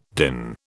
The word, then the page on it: DIN: British English